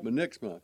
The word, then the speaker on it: Munniksma: It's M-U-N-N-I-K-S-M-A. But you pronounce it Munniksma. You know, I mess it up. I always do. Munniksma. Munniksma. – Munniksma, yeah.